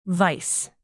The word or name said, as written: vice